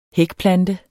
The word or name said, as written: hækplante